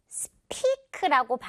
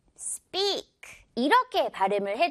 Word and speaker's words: speak: 자, 말하다 라는 단어인데 이거 많은 친구들이 speak라고 발음을 하는 것 같아요. speak가 아니라 S랑 P 이렇게 정말 진한 우정 만난 상황에서는 발음이 어떻게 된다? 그렇죠. 된소리 난다. speak 이렇게 발음을 해주면 훨씬 더 자연스러워요.